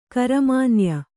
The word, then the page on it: ಕರಮಾನ್ಯ: karamanya